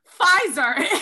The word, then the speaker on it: Pfizer: Pneumonia, psychosis, psychopath, pterodactyl. You know what these all are? Have silent P's. Pfizer is not to be trusted with its little silent P.